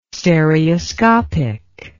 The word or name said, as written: stereoscopic